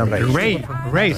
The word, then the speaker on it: Raye: Ray. Se escribe Raye.